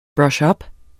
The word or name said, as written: ups